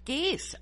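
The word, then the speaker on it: Gies: Is an associate director at the, you say Geis? Is that how you pronounce it? – Geis. – Okay, Geis.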